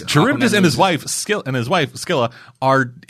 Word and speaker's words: Charybdis: I think it's Charybdis. Charybdis? – Charybdis.